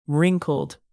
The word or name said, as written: wrinkled